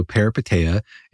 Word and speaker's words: Peripeteia: Peripeteia.